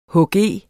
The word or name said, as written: hg